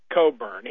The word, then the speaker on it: Cockburn: His name is spelled Cockburn, but he pronounces it Cockburn.